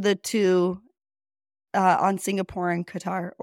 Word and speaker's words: Qatar: It's really Qatar. And I know. Just Americans usually call it Qatar.